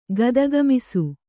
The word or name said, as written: ಗದಗಮಿಸು